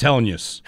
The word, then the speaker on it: Hellenius: Yeah, like use. The word use. – The second part. – Three there. – Syllables. He said it. It's Hellenius.